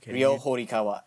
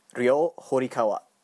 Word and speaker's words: Ryo Horikawa: I mean, if you're going for a Street Fighter type of pronunciation, you'd probably say Ryo, but no. The Japanese language, it has some weird pronunciations when there's a one, like, kind of letter or symbol, mm-hmm. and they combine other small symbols to make another sound. Mm. In this case, it's a ri, R-I, and right next to it would be a small yo, y-o, and this makes the sound ryo.